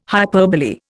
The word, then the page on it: hyperbole: Indian